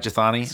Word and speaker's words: Jethani: Did I say that right, Jethani? – Yeah, Skye the Jethani.